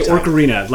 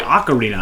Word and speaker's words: Ocarina: I used to say Orcarina, like added an R,